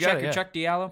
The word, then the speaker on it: Cheick Diallo: Is it Cheick Diallo? – Yeah, I think so, yeah. – I think it's just Cheick Diallo.